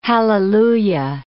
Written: hallelujah

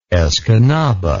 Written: Escanaba

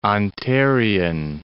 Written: Ontarian

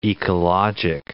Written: ecologic

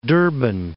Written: Durban

